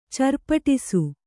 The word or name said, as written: ಚರ್ಪಟಿಸು